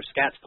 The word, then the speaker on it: Scats: Um, as for uh, for Scats poker, and I don't pronounce it skates. I have no idea who who would do that. It's not like it has a silent Z or anything.